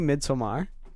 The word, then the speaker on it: Midsommar: Of scary, did you guys see Midsommar? – Is that how you pronounce it? – Yeah, Midsommar.